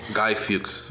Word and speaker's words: Guy Fawkes: Guy Fawkes.